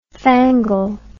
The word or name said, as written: fangled